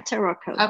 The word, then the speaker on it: Taroko: So let's jump right into the national park, into Taroko. Am I saying that right? Taroko? – Yeah, Taroko.